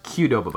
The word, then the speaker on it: Qdoba: It's pronounced Qdoba, by the way. – Qdoba. – It is not Qdoba.